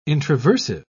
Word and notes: introversive: British English

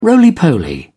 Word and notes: roly poly: British English

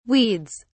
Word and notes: weeds: British English

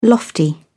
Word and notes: lofty: British English

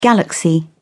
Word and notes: galaxy: British English

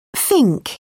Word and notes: fink: British English

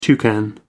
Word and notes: toucan: American English

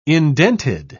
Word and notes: indented: British English

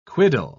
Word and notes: quiddle: British English

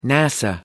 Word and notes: NASA: American English

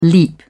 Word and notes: leap: British English

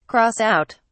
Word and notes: cross out: British English

